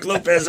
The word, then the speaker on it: Lopes: But you guys, you know, on an uplifting note, you guys have a new awesome vocalist, Mark Lopez. – It's actually pronounced Lopes, but we're going to go with Lopes.